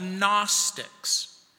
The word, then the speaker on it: Gnostics: They were called the Gnostics. And that word might sound strange to you. It's spelled G N O S T I C S. The G is silent.